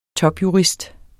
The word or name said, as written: topjurist